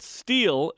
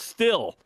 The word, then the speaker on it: steel: I would like to let announcers know that steel is not pronounced still. It has a long E in it because when there are two vowels together like EA in steel, the first has a long vowel sound. EA is a long E sound.